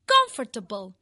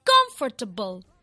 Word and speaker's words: comfortable: Recordeu que la pronunciació de confortable, que és com s'escriu l'adjectiu còmodo, no és comfortable com ens pot sonar a priori, ja que coneixem la paraula table i ens pot semblar que sigui aquesta la pronunciació. No, diem la síl·laba forta al principi de la paraula. Diem comfortable, comfortable.